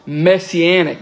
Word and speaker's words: Messianic: Psalm 69 is also a very Masonic psalm. Not Masonic, that thing in the lodge. Messianic.